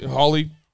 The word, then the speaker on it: Hawley: I mean, I was really grateful to see Josh Haley last week release Holly, H A W L E Y.